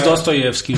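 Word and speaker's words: Dostoiévski: E o The Hospital do Pachaevski. – Pronuncia-se Dostoyevski, Rodrigo. Lamento informar-te.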